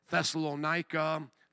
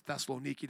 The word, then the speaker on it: Thessaloniki: Thessalonica, Thessaloniki, depending on how you pronounce it. My Greek family pronounces it Thessaloniki.